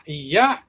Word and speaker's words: 嫌: Ya is the same kanji as hate, but it's, it is pronounced ya. Hate will be kirai, kirai, so try not to confuse it like I did.